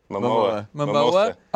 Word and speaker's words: Momoa: Can't Momoa. – Pronounce his last name. – Momoa.